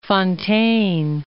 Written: Fonteyn

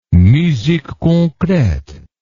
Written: musique concrete

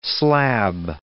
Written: slab

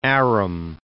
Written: Aram

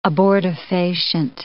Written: abortifacient

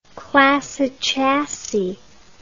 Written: classis chassis

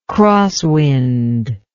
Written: crosswind

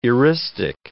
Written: eristic

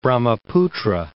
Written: Brahmaputra

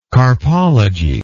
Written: carpology

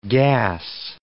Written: gas

